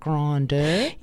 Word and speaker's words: Grindr: Grindr? – Yeah.